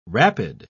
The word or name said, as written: rapid